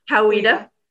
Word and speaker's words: Coweta: Coweta, or is it Coweta? Coweta? – Coweta.